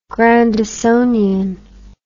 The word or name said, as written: grandisonian